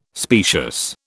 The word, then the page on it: specious: British English